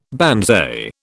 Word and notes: banzai: British English

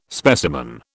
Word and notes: specimen: British English

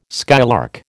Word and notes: skylark: American English

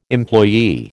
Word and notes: employee: American English

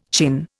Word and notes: chin: Indian

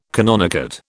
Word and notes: canonicate: British English

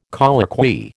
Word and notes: colloquy: American English

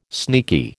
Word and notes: sneaky: American English

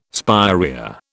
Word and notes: spirea: British English

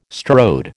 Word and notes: strode: American English

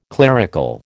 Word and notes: clerical: American English

